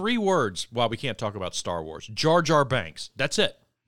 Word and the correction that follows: Jar Jar Binks: You can't even say his name right, by the way. – How do you say it? – It's Jar Jar Binks, and that's the only time in the world you will ever hear me defend Jar Jar.